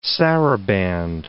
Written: sarabande

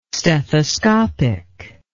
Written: stethoscopic

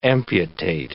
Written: amputate

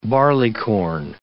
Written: barleycorn

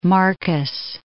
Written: Marcus Island

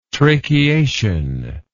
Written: tracheation

